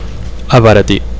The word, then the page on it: abaratir: Catalan